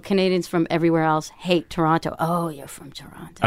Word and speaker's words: Toronto: Toronto.